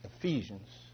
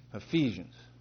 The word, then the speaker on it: Ephesians: When I first became a Christian, I didn't know how to pronounce Ephesians correctly. And when I read it, some people will laugh. But then a brother will come afterwards and go, brother, that's Ephesians. Ephesians. Thank you, brother.